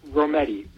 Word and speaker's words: Rometty: To let you know that her name is pronounced Rometty. Virginia Rometty rhymes with spaghetti.